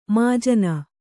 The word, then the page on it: ಮಾಜನ: mājana; Kannada